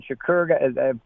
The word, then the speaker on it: Shakur: especially when Shakur, that's how his name's pronounced, got hurt.